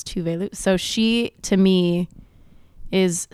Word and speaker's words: Tove Lo: Tove Lo, Tove Lo is the correct pronunciation.